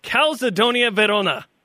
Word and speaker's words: Calzedonia Verona: You signed a contract last week with a team called Calzedonia Verona, something like that in Italy.